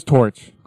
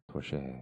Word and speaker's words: Torche: E on the end. That's why. – It's like the French pronunciation with the silent E. – Yeah. Torche.